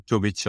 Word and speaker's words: Tubic: It's Ratko Tubic in my language, but Tubic.